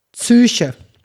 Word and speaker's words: Psyche: Allein das Wort zum Beispiel Psyche. Ich habe es mit der Psyche. Z-Ü-C-H-E. Psyche.